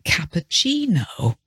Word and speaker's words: cappuccino: So there are words like cappuccino, C-A-P-P-U-C-C-I-N-O. So why is this double C pronounced as a ch sound? Well, because it comes from Italian. Cappuccino, focaccia, fettuccine are examples of Italian words in English with a double C and hence that different pronunciation because of the origin.